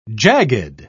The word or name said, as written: jagged